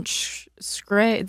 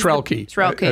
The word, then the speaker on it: Shkreli: Martin Shkreli, the CEO, the infamous pharmaceutical CEO who raised Shkreli. I think is how you say it.